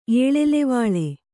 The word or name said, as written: ಏಳೆಲೆವಾಳೆ